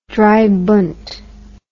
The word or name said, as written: dreibund